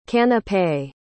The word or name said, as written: canapé